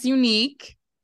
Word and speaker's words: UNIIQU3: So it's pronounced UNIIQU3. And I spell it different because I come different. You know what I'm saying? I bring it fresh. I keep it fresh.